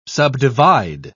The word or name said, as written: subdivide